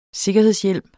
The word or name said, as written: sikkerhedshjelm